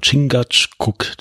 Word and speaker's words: Chingachgook: Und genau das tun wir ja auch, nämlich, ähm, wie spricht man es aus? Chingachgook? – Ja, ich würde auch sagen, Chingachgook, die große Schlange.